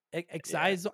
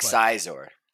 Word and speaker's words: Xizor: X-I-Z-O-R? – Yeah, I don't know. That's Xizor? Xizor? Xizor? I don't know how the fuck you say it. – Xizor. – Yeah, Xizor. Yes, let's go with that. Xizor.